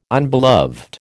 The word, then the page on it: unbeloved: American English